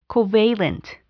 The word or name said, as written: covalent